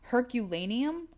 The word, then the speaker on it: Herculaneum: Herculaneum, I'm hoping I pronounced that correct. – I've heard of that. That's I think that's correct.